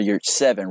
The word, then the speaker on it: Yurtseven: But I did see the final stat line for our guy Omar Yurtseven, right? That's how you say it.